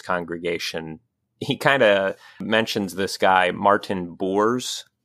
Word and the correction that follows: Boos: Am I saying that right? Martin Boos.